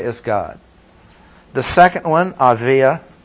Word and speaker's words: Abijah: J, usually in Hebrew, we would say, I know in English, probably say Abijah. Aviah, the J in most of those words is pronounced as though it were a Y. Just like you don't say San Jose. J-O-S-E is San Jose.